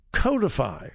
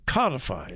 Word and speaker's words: codify: Well, Democrats in the House and the Senate want to codify or codify, depends on how you want to pronounce it, Roe versus Wade.